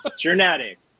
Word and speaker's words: Journatic: Journatic. It was supposed to be like journalism and automatic.